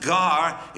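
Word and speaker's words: gar (γάρ): And that's a little word in Greek that's pronounced gar. It's not prominent.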